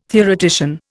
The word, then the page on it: theoretician: Indian